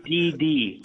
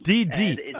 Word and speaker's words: DiDi: Now, BP and, I'm not even sure how to pronounce this, Didi or Didi, to establish EV charging stations in China. Come on. – It's DD. – DD.